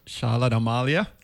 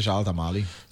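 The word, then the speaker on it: Charlotte Amalie: How you pronounce it? – Charlotte Amalia. – How do you pronounce it? – It's a Charlotte Amalia.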